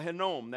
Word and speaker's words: Hinnom: Okay, so we're talking about the Valley of Hinnom. That's how you say it.